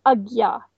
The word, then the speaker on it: Ajna: is called Ajna chakra. That's spelled A-J-N-A, Ajna. Mm-hmm. A-J-N-A.